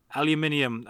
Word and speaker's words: aluminium: Aluminium. I know most of our listeners are American. – Speech. – They're going to mock me for saying it right.